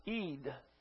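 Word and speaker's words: Eid (עד): That's in the Hebrew, that's ED, and it's not pronounced Ed. Pronounced Eid.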